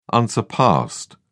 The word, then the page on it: unsurpassed: British English